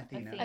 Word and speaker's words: Athena: Athena.